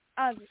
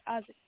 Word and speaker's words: Isaac: Is that Isaac or Isaac? Am I saying it right? – Isaac. Yes, sir. Isaac.